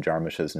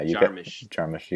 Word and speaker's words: Jarmusch: Pointed out that you repeatedly mispronounced Jim Jarmusch's name on the last episode. – Oh, really? How do you pronounce it? – Jarmusch. – Jarmusch. Jarmusch. – Yeah, Jarmusch. You kept saying Jarmusch.